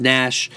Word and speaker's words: Gnash: Of course, that's Gnash spelled with a silent G at the front.